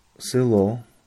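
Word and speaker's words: sylo (село): I tutaj mamy słynne przykłady jak sylo, zelenej czy zernyna. Pisane jako selo, zelenej, zernyna.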